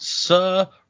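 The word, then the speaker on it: Sarene: And then our princess, it says that her name is pronounced Sa-Re-Ne or Sa-Re-Ne if you want to get technical. – Oh, I was just going to call her Serene.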